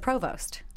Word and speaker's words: provost: Do I say that right? Is it provost? – Yeah.